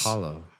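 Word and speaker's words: Hallow: You have that Halo app. The Hollow app, which is Hollow. We said that right.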